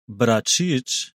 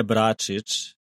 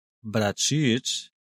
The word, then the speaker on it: Bračič: Eh, z, je, z nami je Igor Bračič, ne Bračič, Bračič.